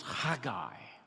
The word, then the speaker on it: Haggai: If I'm to say it properly, I'll probably cover you all in spit, because it's Haggai.